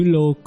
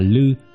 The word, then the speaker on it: Lư: Một Khuôn Lô, chỉ sơ tổ Tịnh Tông tức ngài Quệ Diễn, ngài dựng liên xã nơi núi Khuôn Lô, chữ Lô còn đọc là Lư, nên có sách viết là Khuôn Lư.